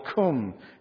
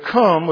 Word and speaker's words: Khnum: And Heqet, the frog goddess, was married, and let me just give you Egyptian religion, was married to the creator god called Khum. If you're taking notes, it's spelled K-H-N-U-M. And Egyptian religion said that Khum was the creator god and that he created human bodies on a spinning wheel.